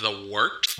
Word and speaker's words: Wort: It is The Wort, not warts, not forts, not short, The Wort.